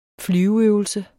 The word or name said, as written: flyveøvelse